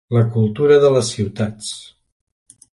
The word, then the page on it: ciutats: catala